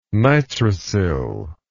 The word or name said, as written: nitrosyl